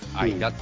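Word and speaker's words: 吾平: 五の、数字の 5 を書いて、下に口って書いてさ、平って書いて、あいらって読むのね。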